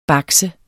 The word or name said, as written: BAKS